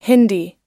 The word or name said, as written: hindi